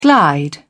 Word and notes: glide: British English

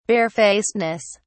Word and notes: barefacedness: British English

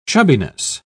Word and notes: chubbiness: British English

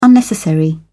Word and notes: unnecessary: British English

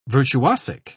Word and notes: virtuosic: British English

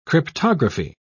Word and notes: cryptography: British English

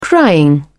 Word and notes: crying: British English

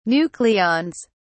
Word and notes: nucleons: British English

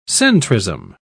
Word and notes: centrism: British English

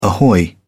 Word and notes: ahoy: British English

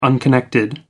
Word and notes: unconnected: American English

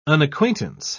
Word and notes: unacquaintance: British English

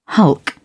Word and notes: hulk: British English

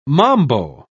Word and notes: mambo: British English

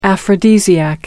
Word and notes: aphrodisiac: American English

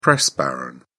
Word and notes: press baron: British English

